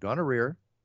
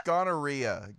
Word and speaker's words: gonorrhea: You're saying it wrong, Buffer. It's gonorrhea. Gonorrhea, not gonorrhea. – That's like diarrhea.